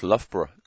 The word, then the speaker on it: Loughborough: Very impressed that you pronounce Loughborough correctly. – Loughborough. – Not yet. Not Lugabaruga, or it's, I think some of the locals call it Lugabaruga.